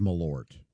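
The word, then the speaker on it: Malört: Okay, now, Miss Hooker has just walked in, And it's the pronouncer that I have from the Associated Press says Malort. – Yeah, I think we're going to ignore that. – Okay. – Because everybody says Malort.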